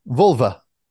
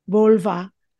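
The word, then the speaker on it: völva: And Viking healers, I believe they were called, and I want to say it properly because it's V-O-L, Volva. Is that the right way to say it? Say it? – Yeah, Volva. – Volva.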